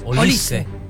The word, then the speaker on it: Olise: El propio jugador ha aclarado que la pronunciación más fiel de su apellido es acentuando la I y cortando la última sílaba sin alargarla. O sea, tenías razón vos, Marcelo, es Olise.